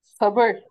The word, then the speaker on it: sabır: And sabır, how do you say it? – Sabır, S-A-B-I-R.